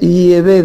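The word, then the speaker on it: Yahweh: Sin embargo, parece ser que la pronunciación más correcta en idioma hebreo arcaico, el antiguo, el idioma bíblico hebreo, es Yehvé de los ejércitos.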